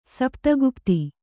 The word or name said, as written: ಸಪ್ತಗುಪ್ತಿ